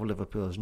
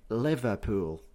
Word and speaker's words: Liverpool: So, for example, instead of saying Liverpool, we will pronounce it Liverpool, Liverpool, almost like Liverpool. Liverpool. It's Liverpool, just said quickly.